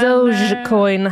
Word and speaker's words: Dogecoin: Men, Dogecoin, eh, jag sa det. Just det. Ja. Helt korrekt uttalat.